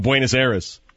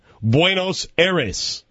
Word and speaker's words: Buenos Aires: Well, I need a naked man running around Buenos Aires. Did I say that right? – No. – Oh. – With Stugatz and Hawkman. – Let's try it again. Buenos Aires.